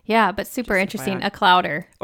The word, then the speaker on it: clowder: Clowder? – Like chowder, but clowder.